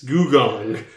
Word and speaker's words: Gugong: Yes, Goo Gong. GUGONG. Typical spelling. Goo Gong.